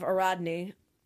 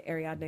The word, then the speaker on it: Ariadne: And the game is the legend of Ariadne, or Ariadne. Mm-hmm. Depending on how you pronounce it. I say Ariadne because I looked it up and that's how it says, but probably said it different ways.